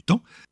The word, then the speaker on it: taon: Et je ne parle pas du taon, du temps, cette mouche qui partage la prononciation avec son pote qui fait la roue.